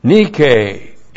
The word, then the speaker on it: nike: Nike, N-I-K long E.